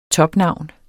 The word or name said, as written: topnavn